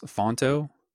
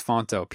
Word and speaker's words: Phonto: That's just the word Over, O-V-E-R. On Android, you can use, I think it's pronounced Fonto. I'm pretty sure that's it. It's P-H-O-N-T-O. I think it's font plus photo combined. I can't really tell, but I think it's Fonto, P-H-O-N-T-O.